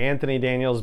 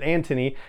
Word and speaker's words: Anthony Daniels: And then I know I, you know, Americanize it and say Anthony Daniels, but he pronounces it Antony.